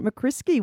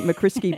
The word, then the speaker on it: McCriskey: Introducing you to a writer, researcher and artist, Sarah Jane McCriskey. Welcome to Afternoons. – Oh, thank you so much. That was actually fantastic pronunciation of my surname. – It sounds relatively innocent saying McCriskey, but if you saw the way it is spelt, yeah.